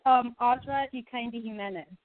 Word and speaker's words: Audra Jimenez: It's Audra Jimenez.